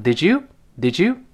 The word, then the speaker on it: Did you: Did you 的旁的耶发音变成 z，读成 Did you？Did you？